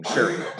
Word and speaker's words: Shuri: Shuri.